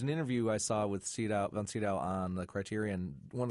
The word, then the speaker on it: Sydow: And it is, in fact, Von Sydow. But he said that it was confusing even for Swedes because it is, in fact, a German name, right.